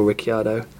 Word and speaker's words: Ricciardo: Of course, Red Bull opted not to go for Kimi, be that whether they felt they had to go for Daniel Ricciardo, and I am pronouncing his surname correctly, which he doesn't, just to stipulate.